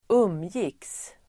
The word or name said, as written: umgicks